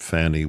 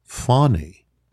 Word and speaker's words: Fani: Oh, by the way, Philip, her name is not Fannie Willis. It's Fonnie. Fonnie. You pronounce it Fonnie Willis, just like it's not Kamala Harris.